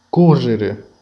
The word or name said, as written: correre